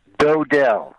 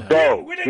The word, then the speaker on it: Dodell: Dodell. – Dodell. – No, Dodell. D like in dough, like money, you know, bread.